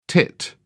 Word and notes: tit: British English

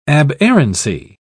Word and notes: aberrancy: British English

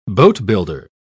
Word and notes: boatbuilder: British English

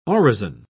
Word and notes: orison: British English

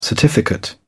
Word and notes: certificate: British English

